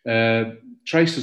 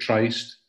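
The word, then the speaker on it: Tryst: Trist is a strange word, and I've used it in some of my titles. Some people pronounce it Trist, people in Ayrshire, for example, but I hear a lot of people wanted to say Trist. There's no wrong or right way of pronouncing it.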